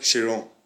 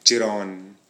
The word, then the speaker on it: Chiron: Wait, is that the Veyron or the Chiron? – Chiron. – it's called Chiron. – Okay, Chiron. – Yeah, a lot of people pronounce it wrong with like Chiron or like Chiron. No, it's called the Chiron.